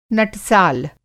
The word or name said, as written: नटसाल